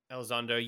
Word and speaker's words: Elizondo: Elizondo, but he plays, I think. Yeah. – Elizondo, yeah.